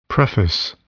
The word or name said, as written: preface